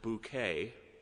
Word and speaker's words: Bucket: In the British made-for-TV comedy, Keeping Up Appearances, Hyacinth Bucket, who insists that her last name is pronounced Bouquet,